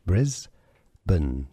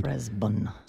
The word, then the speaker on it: Brisbane: Briz, so you could spell it B-R-E-Z. Brisbane. So that's a schwa sound. – Brisbane. – Stress on the first syllable.